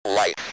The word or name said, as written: life